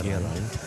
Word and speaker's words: Geraint: I would, my dad would love me, having given me my name, an ancient Celtic name, Geraint. That's the pronunciation of which no one else can get, so as a young migrant boy, I have to be very vicious with that care.